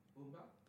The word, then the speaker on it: Booba: I think it's uh, French rapper, uh, rapper Boomba. Oh my God, I've said the name wrong. Excuse me. Booba? – Booba, Booba is coming.